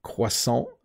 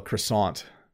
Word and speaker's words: croissant: If you want the French pronunciation, I'll do my best job. It's croissant. But this in Canada, we call a croissant. Croissant. This doesn't sound right, does it? But that's how English speakers say it.